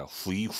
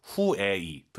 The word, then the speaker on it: 回: 자 그럼 집에 가다 후이 후이 하지 마시고요. U, I 사이에 에 넣어서 후에 이 들어볼게요.